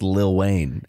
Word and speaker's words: Lil Wayne: It's pronounced Little Wayne. – It's pronounced Lil Wayne. How it's spelled. – It's spelled L-I-L, but it's pronounced Little Wayne.